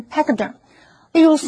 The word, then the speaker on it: pachyderm: Peter 承认这个点子是盗用同事的。所以我们看的是 pachyderm 这个名词 ,D-E-R-M 这个字根有皮肤的意思。所以 pachyderm 是指厚皮动物，例如大象、犀牛或者是河马等等。G-C-H 在这里是发 k 的音，念成 pachyderm。例如 ,some local Africans do not like elephants and complain that the pachyderms are ruining their farms.